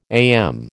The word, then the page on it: AM: American English